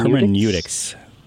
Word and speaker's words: hermeneutics: Hermeneutics? – Hermeneutics.